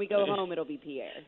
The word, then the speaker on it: Pierre: So it's spelled Pierre, and Chris Lecoq, our producer, who is indeed French, says only the Yankees would say things weird like that. But it's Pierre. That's how they say it here.